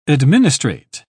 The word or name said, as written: administrate